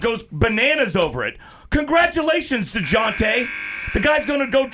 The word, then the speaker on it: Dejounte: Is it DeJounte Murray? DeJounte Murray? – You got it right the first time. – DeJounte.